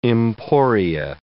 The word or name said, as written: emporia